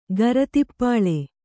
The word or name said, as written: ಗರತಿಪ್ಪಾಳೆ